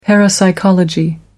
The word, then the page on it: parapsychology: American English